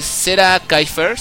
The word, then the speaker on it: zerakyfer: Ahí está el buen Fernando, lo pueden encontrar en Twitter como arroba Serakifer, si no lo estoy pronunciando mal. Si no, es Serakifer, bueno, es que es respecto al cómic, el nombre del...